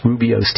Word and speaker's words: Rooibos: That's spelled R-O-O-I-B-O-S, Rubio's tea or Rooibos tea.